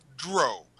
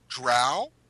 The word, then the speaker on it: drow: You can pronounce drow elves as drow. So if you want to say drow, that is correct. If you want to say drow, that is correct. Neither way is incorrect.